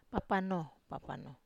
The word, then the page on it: papano: wamesa